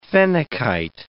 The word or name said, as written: phenakite